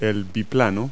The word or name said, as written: biplane